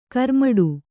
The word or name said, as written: ಗಿರಿಮಿಟ್ಟು